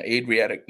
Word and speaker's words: Adriatic: Is it the Adriatic? – Yeah, Adriatic North Mission.